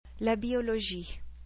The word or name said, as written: La biologie